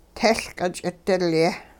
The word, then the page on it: tehLqit-ch'idilye: Hupa